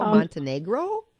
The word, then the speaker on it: Montenegro: A friend and I are looking for 7 to 8, maybe 9 day cruise to Croatia with a visit to Monte de Nero. Am I saying that right? – Monte de Negro? Montenegro? – There we go.